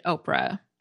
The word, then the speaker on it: Orpha: According to the American Name Society, Oprah Winfrey's actual first name is spelled O-R-P-H-A, Orpha, after a character in the Book of Ruth in the Bible, but her family always pronounced it Oprah.